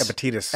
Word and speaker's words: Epictetus: Epictetus.